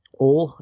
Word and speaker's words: Ole: And I wanted to throw some love at the, uh, at the oil, at the oil drillers Ole, I guess I said that right. From from Arkansas, you know, one syllable, not two.